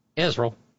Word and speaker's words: Israel: We're pronouncing Israel the way that Mika does. Israel.